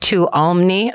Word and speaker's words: Tuolumne: Ellie walked into the Tuolumne, pronounced Tuolumne. Oh, I did it.